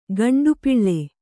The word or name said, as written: ಗಂಡುಪಿಳ್ಳೆ